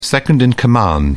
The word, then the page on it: second-in-command: British English